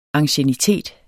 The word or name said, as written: anciennitet